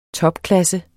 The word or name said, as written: TOPKLASSE